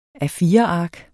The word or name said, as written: A4-ark